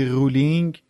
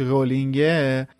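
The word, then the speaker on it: Rowling: چرا میگی رولینگ درستش رولینگه یه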